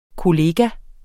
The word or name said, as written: kollega